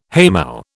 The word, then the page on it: haymow: British English